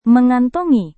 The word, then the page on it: mengantongi: Indonesian